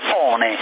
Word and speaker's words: One: Allora, te ne segnalo un altro omologato che non ho detto prima, il CRT One, scritto ONE, si pronuncia One in inglese, no, sarebbe CRT 1, anche questo omologato, come quello che ti arriva a te, CE0700.